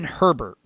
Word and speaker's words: Herbert: It's Justin Herbert, right? It's Herbert, right. – Not Hebert. Yeah. All right. I just want to get Herbert. The pronunciation correct. I keep saying Justin Hebert, and I'm like, it's not.